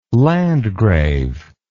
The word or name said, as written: landgrave